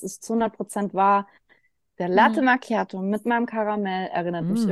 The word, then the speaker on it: Latte Macchiato: Latte Macchiato. Das hast du schön ausgesprochen.